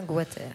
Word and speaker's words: Goater: Je ne sais jamais comment prononcer les éditions Goater. – Je crois que c'est bien Goater.